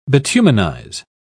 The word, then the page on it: bituminize: British English